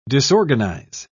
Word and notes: disorganize: British English

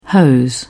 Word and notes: hose: British English

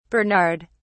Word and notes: bernard: British English

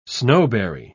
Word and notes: snowberry: British English

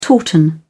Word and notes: tauten: British English

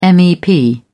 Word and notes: mep: British English

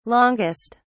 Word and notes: longest: British English

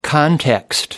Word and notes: context: American English

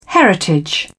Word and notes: heritage: British English

